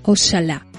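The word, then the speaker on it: oxalá: Esta interjección, con la que se manifiesta el deseo de que ocurra algo, se expresó durante algún tiempo con la forma Oshalá, la X pronunciada como la CH francesa o la SH inglesa.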